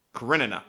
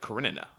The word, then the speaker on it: Karenina: Karenina novels were also Karenina. Inspiration. What's up? – Karenina. – Okay, Karenina.